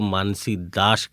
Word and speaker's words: मानसी दास: लेकिन उनका उनका जो अपना नाम है वो खुद को मानसी दास कहती हैं, वो उड़ीसा की रहने वाली हैं और उनके यहाँ इस नाम का उच्चारण इसी तरह किया जाता है।